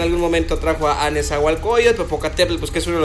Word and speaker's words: Popocatépetl: Ahí está, o sea, escuchen la pronuncia, Popocatépetl, o sea, lo como es, Popocatépetl,